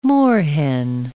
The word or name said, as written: moorhen